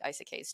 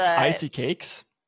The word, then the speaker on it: isekai: I just like I like Portal Fantasies, which I guess I should like Isekais too, but I love the Isekais. – How do you pronounce it? – Isekai. – Isekai.